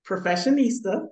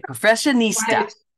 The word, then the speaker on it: Professionista: And alongside of that, Professionista, that is how you say it. – Okay, Professionista.